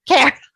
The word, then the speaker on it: Kaehr: Care.